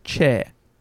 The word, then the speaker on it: Choi: So her name is spelt C-H-O-I, which often when pronounced in Western languages is Choi. – Mm. – But they apparently say, and I'm attempting this here, Chair.